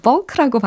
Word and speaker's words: balk: 네, 그래서 bulk라고 어, 발음을 하는 동사이고 아, 이게 의미는요.